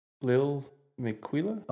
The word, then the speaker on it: Lil Miquela: La Miquela. How would you say that? – Lil Miquela? – Oh, did Lil? I say La? Yeah, yeah. Lil, Lil Miquela.